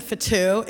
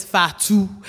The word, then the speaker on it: Fatou: And they were reminding me that uh, my name isn't Fatou, it's Fatu.